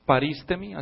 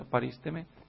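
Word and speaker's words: parísteme (παρίστημι): En griego, el idioma en que se escribió el Nuevo Testamento, la primera vez, el verbo que se usa aquí, parísteme, así se pronuncia, parísteme, significa, está formado de dos vocablos.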